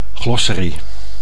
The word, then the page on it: glossary: Dutch